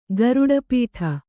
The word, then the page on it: ಗರುಡಪೀಠ: garuḍa pīṭha